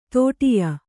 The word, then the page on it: ಧಡಯ: dhaḍaya